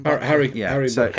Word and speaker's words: Butker: Harrison Butker.